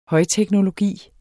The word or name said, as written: højteknologi